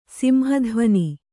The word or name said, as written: ಸಿಂಹಧ್ವನಿ